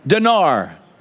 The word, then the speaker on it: Dinar: I, how you say it? Dinar.